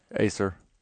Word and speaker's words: Aser: A-S-E-R. How would you pronounce that? – Acer. – Acer. Yeah.